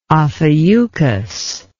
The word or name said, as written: Ophiuchus